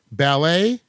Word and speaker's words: ballet: What silent letter ends these words? Ballet, debut, depot. – T.